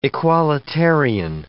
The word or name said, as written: equalitarian